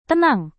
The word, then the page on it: tenang: indonesian